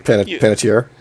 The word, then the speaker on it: Panettiere: I do like Hayden Panda Bear, whatever. I can't fucking pronounce her name. – Panettiere.